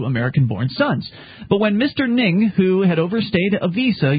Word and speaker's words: Ng: 15 years later, Hugh Lee Ng, his last name is spelled N-G. – Ng? Ng. Ng. – Yeah. Ng.